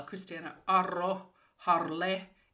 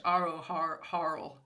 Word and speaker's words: Christiana Aro-Harle: Pretty well. Christiana Aro-Harle in Finnish, or Aro, I can't say it in English, Aro-Harle.